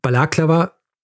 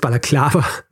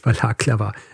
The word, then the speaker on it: Balaklava: Also ja, es heißt Balaklava. Und nicht, es heißt Balaklava und nicht Balaklava.